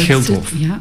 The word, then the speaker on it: Gildhof: En vervolgens gaan we ook nog eens voor een zestal weken naar café de foyer van het Gildhof. – Ja, zeg ik dat goed? – Ja, je zegt dat goed.